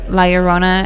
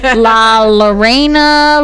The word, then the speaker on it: La Llorona: Okay. Say it with me. La. – La. – Yo. – Yo. – Ro. – Ro. – Na. – Na.